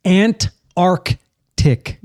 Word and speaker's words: Antarctic: Okay, Antarctic. – Antarctic. – Yeah, that is correct. Yeah. I probably didn't say that right. – Okay. – So, and that's because the two C's are the trouble.